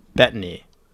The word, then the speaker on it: Bettany: I thought Paul Bettany, how do you say his last name? – I just say Bettany. – Bettany. It's Bettany. Bettany.